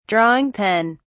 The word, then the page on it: drawing pen: British English